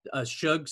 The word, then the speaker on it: Shug: I'm excited about Shug Sutton. I always want to say Sug mm-hmm. because that's how it's spelled.